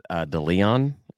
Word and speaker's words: DeLeon: His middle name is DeLeon. I don't know if I'm pronouncing that correctly. D-E-L-E-O-N. D-E-L-E-O-N.